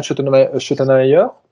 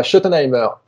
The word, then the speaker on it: Schottenheimer: Alors, vu de ma fenêtre, j'avais l'impression que ça tournait quand même plutôt bien avec Kellen Moore, et maintenant, vous avez Brian Schottenheimer, hein, voilà, c'est très mal prononcé, mais c'est pas grave. – Schottenheimer.